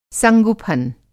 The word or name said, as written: संगुफ़न